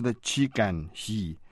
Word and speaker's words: Qi Ganxi: His name was Qi Ganxi, pronounced qi gan he, which could be difficult for non-Chinese to manage.